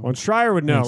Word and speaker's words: Schreier: How do you say it? Schreier? – Yeah.